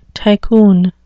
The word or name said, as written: tycoon